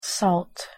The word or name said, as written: salt